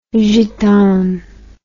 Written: gitanes

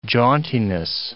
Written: jauntiness

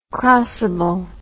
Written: crossability

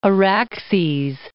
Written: Araxes

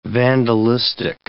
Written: vandalistic

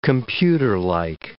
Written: computerlike

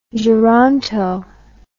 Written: geronto

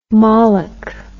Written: mollock

